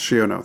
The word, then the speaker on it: Shiono: Shiono? – Yes, Shiono.